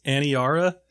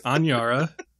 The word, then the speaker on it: Aniara: I kept calling this Aniara. – Oh, me too. – Which is not right. It's Aniara.